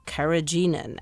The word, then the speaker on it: carrageenan: But then I read words like carrageenan.